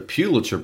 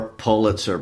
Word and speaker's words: Pulitzer: Well, just by way of clarification, in case someone doesn't know, the Pulitzer Prize or Pulitzer Prize, or you hear both pronunciations, I'm going with Pew.